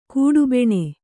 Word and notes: ಕಿಡಿಬೆಣೆ: kiḍibeṇe

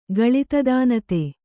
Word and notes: ಗಳಿತದಾನತೆ: Kannada

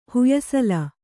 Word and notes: ಹುಯಸಲ: huyasala

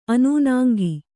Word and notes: ಆನೆನೂಂಕು: ānenūŋku

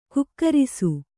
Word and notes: ಗೂಗರಿಸು: gūgarosi

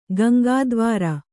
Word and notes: ಗಂಗಾದ್ವಾರ: Gaŋgā dvāra

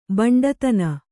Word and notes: ಬಂಟುತನ: baṇṭutana

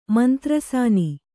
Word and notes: ಮಂತ್ರಸಾನಿ: Kannada

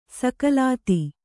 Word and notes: ಸ್ಖಲಿತ: skhalita

